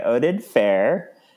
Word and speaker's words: Oded Fehr: Yeah, because he's played by Oded Fehr. I think that's how you say his name. Oded yes. Fehr. – Yep, that's how I would say it.